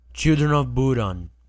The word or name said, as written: Children of Bodom